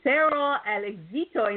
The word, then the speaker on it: Cero al Éxito: She is a lady change maker coach, public speaker, co-author of two books for entrepreneur, dominated decades in English, and uh, De Cero al Éxito in Espanol. Did I say it right? cero al Éxito, así es.